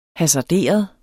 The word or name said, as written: hasarderet